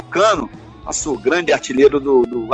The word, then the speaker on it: Cano: Fechando então com Dance de la Subvivance, faixa que é do Cano, ou do Cano, como prefere aí na pronúncia do francês.